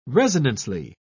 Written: resonantly

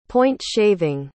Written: point-shaving

